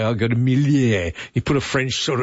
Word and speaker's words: Miele: It's pronounced Miele. It rhymes with Sheila.